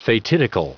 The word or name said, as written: fatidical